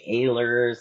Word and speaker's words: Ehlers: And so some people say Ehlers, some people say Eller.